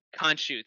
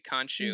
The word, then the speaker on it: Khonshu: Wasn't really much of a trial as it was just a, oh, we just don't believe I'm never going to pronounce his name right. The Moon Knights. – Khonshu. – Thank you. Khonshu.